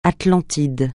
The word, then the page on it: Atlantide: francais